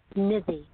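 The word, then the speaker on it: Nidhi: Nizzy. – Can you spell that for me? – It's N-I-D-H-I.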